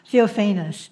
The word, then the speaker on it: Theophanes: The next is Rosa Theophanes. Please correct me if I'm not pronouncing it correctly. – It's Theophanes. – Theophanes.